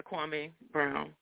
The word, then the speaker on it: Kwame Brown: Kwame Brown? How you pronounce the man name? – Yeah, Kwame Brown.